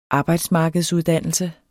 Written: arbejdsmarkedsuddannelse

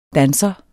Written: Danseren

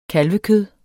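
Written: kalvekød

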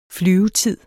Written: flyvetid

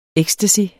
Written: ecstasy

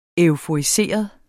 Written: euforiseret

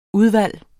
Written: udvalg

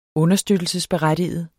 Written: understøttelsesberettiget

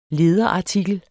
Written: LEDERARTIKEL

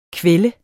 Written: kvælde

